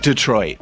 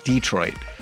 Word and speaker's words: Detroit: And it is Detroit, by the way. – Not Detroit. – Yes, because I went to Michigan and that's how they say it. Detroit.